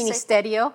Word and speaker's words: Ministerio: Ministerio.